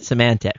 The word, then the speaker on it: Symantec: I work for them, not in that area, but and so it just kind of does great when you mispronounce that particular name. So say it with me 10 times. Symantec. – You mean Symantec? Symantec.